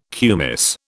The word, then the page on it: kumis: British English